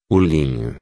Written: linho

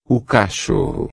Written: cachorro

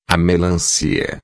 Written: melancia

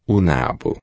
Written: nabo

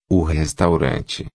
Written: restaurante

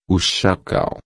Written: chacal

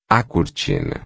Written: cortina

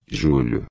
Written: julho

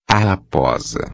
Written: raposa

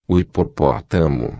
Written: hipopotamo

